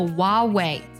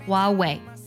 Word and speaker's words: Huawei: 它的英文名不少人会读作 Huawei，当然也有很多人啊，坚持把那个赫的音读出来，读作 Huawei。存在竞争里，这两种发音呢都是 acceptable 的，都是可以的。